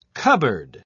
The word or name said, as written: cupboard